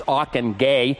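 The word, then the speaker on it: Ockenga: and whose name everyone doesn't seem to know how to pronounce, it's Ockenguy.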